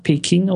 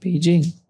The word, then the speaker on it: Beijing: Und äh, früher hieß, früher haben die Leute das alles noch, noch Peking ausgesprochen, also sehr hart. – Das mhm. ist halt weicher geworden, jetzt heißt es halt Beijing.